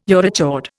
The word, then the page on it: urochord: Indian